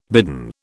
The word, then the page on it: bidden: British English